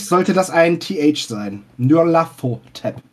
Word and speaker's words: Nyarlathotep: Nyarlathotep. – Spricht man den so? – Nyarlathotep.